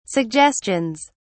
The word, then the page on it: suggestions: British English